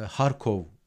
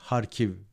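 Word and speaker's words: Kharkiv: E, ülkenin doğusunda e, Harkov diye okunuyor Türkçe'de. Harkiv veya Karkiv diye okunan okuyanlar da var.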